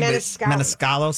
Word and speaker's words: Maniscalco: No, you got it right. You're close. Meniscalco, I believe. Meniscalco.